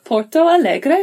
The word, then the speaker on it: Porto Alegre: You are originally from Porto Alegre. Am I saying that right? – Yeah, that's good. – In the south of Brazil.